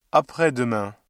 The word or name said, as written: après-demain